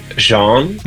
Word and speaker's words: Jean: Because it's spelled J-E-A-N. – Is pronounced differently every time because I pronounce it Jean, and a lot of people say Jean.